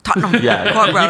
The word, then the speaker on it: Tottenham Court Road: Tottenham Court Road. – Tottenham Court Road.